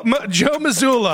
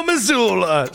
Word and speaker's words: Mazzulla: Crazy Joe Mazzulla. I still butchered that. I'm going to do that again. Crazy Joe Mazzulla.